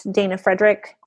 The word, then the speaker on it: Dana Fraedrich: You can also just find me at Dana Fraedrick on Instagram.